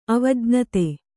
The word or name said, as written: ಅವಜ್ಞಾತ